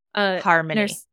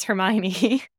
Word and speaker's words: Harmony: Because Harmony, you know, should have a Y, but this is an IE, and I read it as Hermione, and I was like, oh, Harry Potter trial.